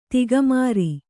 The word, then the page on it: ತಿಗಮರಿ: tigamari